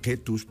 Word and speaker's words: Cetus: As dúas venían do latín Cetus, bueno, Ketus, o pronunciaban eles,